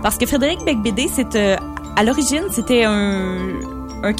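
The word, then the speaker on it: Beigbeder: Donc, tu nous parles de quoi aujourd'hui, Bec Bédère? – Non. – Bec de Bébé? – Je parle d'un livre de Frédéric Bec Bédé. – Bec Bédé. – Ça s'écrit E-R, mais ça se prononce E.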